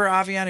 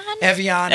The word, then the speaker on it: Evian: Do you remember Avion? Have you seen Avion? – Evian.